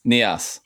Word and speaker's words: Nias: And they are Nias? How do you pronounce that? – Yep. – Nias, okay.